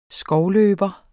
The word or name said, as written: skovløber